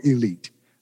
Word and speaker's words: Elite: Roy Campanella comes to the Negro Leagues when he was 15 years old as a member of the Baltimore Elite Giants. Now, of course, it's spelled elite, but it is pronounced elite. And you make those Negro Leaguers mad if you call them the Elite Giants.